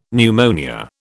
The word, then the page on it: pneumonia: British English